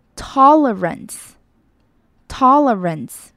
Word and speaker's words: tolerance: 最后看到 tolerance 的 o 的发音要注意是 o。 tolerance，tolerance。